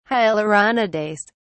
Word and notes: hyaluronidase: British English

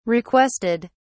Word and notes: requested: British English

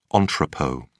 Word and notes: entrepot: British English

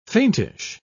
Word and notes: faintish: British English

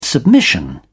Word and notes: submission: British English

